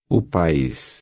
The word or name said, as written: país